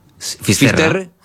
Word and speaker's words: Fisterra: Entonces, llegó a Finisterre. ¿Se dice así? – Fisterra.